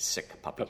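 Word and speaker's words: SICPP: And we had to keep the two P's, so it's still pronounced Sick Puppy.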